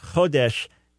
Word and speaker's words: Chodesh: Yeah, the first thing, the first word, Chodesh, so you did a good job on it, but accent on the first syllable. Chodesh, in itself, it means month.